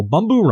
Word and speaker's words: Bumbu: Well, Bumbu Rum. – B-U-M-B-U or B-U-B-U? – No, B-U-M-B-U. So Bumbu.